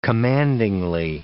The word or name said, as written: commandingly